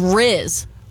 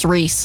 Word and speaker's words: Rhys: It's spelled R-H-Y-S. – R-H-Y-S? – Yes, ma'am. – Reese? – Is that Reese? – I had a kid in my class whose name was spelled like that, and he said it was pronounced Reese.